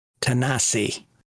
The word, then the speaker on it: Tennessee: And it's pronounced Tanasi. Tanasi. – Yeah. – It's the proper pronunciation.